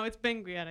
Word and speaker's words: Benguiat: I looked up how to pronounce this. Benguiat. Benguiat. – What is it? Let me see it. – Benguiat. – Right, where is it? – Benguiat. Benguiat. – Benguiat. Benguiat? – No, it's Benguiat, I think.